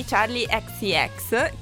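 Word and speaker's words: Charli XCX: Ho deciso di parlare di un album che è uscito di Charlie XCX, che tu mi hai insegnato si pronuncia così, io lo chiamavo soltanto Charlie, che è uscito il 13 settembre in realtà, quindi qualche...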